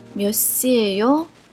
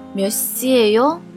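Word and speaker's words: 몇시예요: 今天我们要学的一句是 몇 시 에 요， 몇 시 에 요，几点了的意思。这里的 예 在口语上可以读成 a a， 몇 시 에 요。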